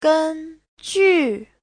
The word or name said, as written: gen1ju4